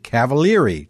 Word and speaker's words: Cavaliere: I found out that he pronounces the E at the end of his last name like an E, Cavaliere, just like I pronounce the E at the end of my last name as an E.